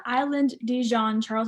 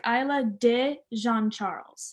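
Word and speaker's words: Isle de Jean Charles: Also, I want to clarify, it's not Island de Jean Charles, it's Island, it's like Isla de Jean Charles.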